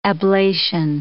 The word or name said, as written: ablation